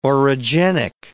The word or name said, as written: orogenic